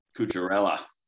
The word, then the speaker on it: Cucurella: Cucurella. – Cucurella, 9 points.